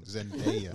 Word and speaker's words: Zendaya: No, Zendaya's bad. I said her name wrong. Zendaya.